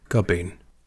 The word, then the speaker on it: Gubbeen: Or pronounced in the Irish way, Gubbeen. – Gubbeen. What did I say? – No, I was just doing it with an Irish accent.